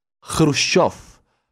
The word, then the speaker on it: Khrushchev: you pronounce Khrushchev. Khrushchev. There is no K in Khrushchev.